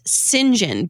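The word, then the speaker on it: St John: My favorite, and this is such a detail, but my favorite part was when I realized that the name STJOHN is pronounced Sinjin, because I was reading, listening to the book, and I was like, Sinjin, that's an odd name, but okay.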